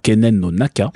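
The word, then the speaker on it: Kenen no Naka (犬猿の仲): Ça vient d'une expression japonaise qui se prononce Keinen no, Nonaka. Je l'ai raté. Attends, Kenen no Naka.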